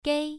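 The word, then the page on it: gei: tone 1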